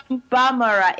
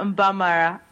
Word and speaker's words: Mbamara: Mbamara. It's Vanessa Mbamara.